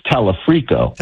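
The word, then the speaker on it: Talarico: If you and I sit here on November the 4th and 5th and say, okay, we got Dr. Abdul, we have Tallarico, we have Angie Nixon, do you and I not perceive reality? Is that possible? – I think you're mispronouncing his name, Bill. It's Tallarico.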